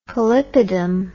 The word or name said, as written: polypidom